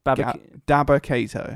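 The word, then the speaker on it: Dabba-Kato: He's 6 foot nine, Baba Kato. Or is it Gabba, what was it? – Dabba Kato. – Dabba Kato.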